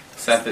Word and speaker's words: Seth: Seth Stewart, and I am the Chief of Staff for Councilmember Dan Kalb. – Can you spell that for us? – Kalb is... – Your name, sorry. – Seth is S-E-T-H. Mm-hmm. Stewart is S-T-E-W-A-R-D.